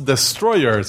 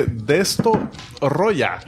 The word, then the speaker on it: Destoroyah: Y no es Destroyer, sino que Destoroyah.